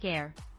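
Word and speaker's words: Aire: Air, pronounced like Air.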